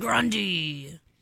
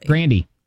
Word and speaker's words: Grande: Ariana Grande. You're saying it correctly. – Grandy.